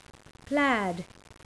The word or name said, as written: plaid